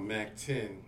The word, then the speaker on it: Mactyn: It's called Mac 10, you know, and it's spelled MACTYN.